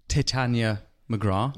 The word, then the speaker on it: Titania McGrath: You're the man behind Titania McGrath.